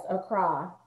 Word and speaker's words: Accra: And you said it's pronounced Accra, right? – Yes, Accra, Ghana.